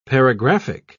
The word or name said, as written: paragraphic